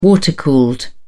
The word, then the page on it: water-cooled: British English